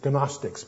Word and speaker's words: Gnostics: G, and it's Gnostics, but pronounced Gnostics, the G is silent.